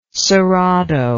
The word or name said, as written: cerrado